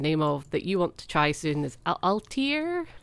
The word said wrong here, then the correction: Atelier: Oh, Atelier? that's it.